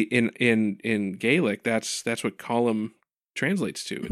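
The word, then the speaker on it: Colm: It's pronounced column? – I know, that's really, like...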